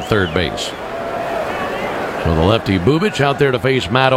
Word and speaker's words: Bubic: Spelled B-U-B-I-C. Pronounced Chris Bubich.